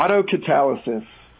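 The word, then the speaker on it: autocatalysis: Yeah, auto catalysis.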